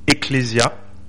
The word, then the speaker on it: ecclesia: Alors, je fais pas de grec, mais c'est le mot grec qui se prononce ecclesia.